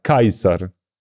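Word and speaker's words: Caesar: I na przykład wiemy to, że e, k, g i c to, to był jeden dźwięk, czyli był na przykład kajus, nie gajus, tylko kajus, Julius. Tak? tak, Kajsar. Tak się to wymawiało.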